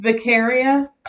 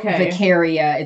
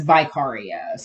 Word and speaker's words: Vicaria: it's Vicaria, yeah, because she pronounces her name as if you're saying vicariously. So it's not Vicaria, it's Vicaria. So it's supposed to be, because it's we find out later it's a play on Victor, who's Victor Frankenstein.